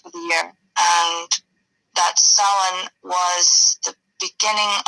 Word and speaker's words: Samhain: Which is spelled, and what probably people from like America would pronounce it as it's spelled in English, Samhain, but in Irish it is Samhain, or yeah. Depending on the part of the country, Samhain or Samhain.